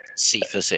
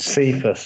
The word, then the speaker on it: Cephas: It's more Raymond, and I'm going to pronounce this right, but is it Quintez Cephas? – Cephas it is. – Cephas, sorry, yeah, there you go.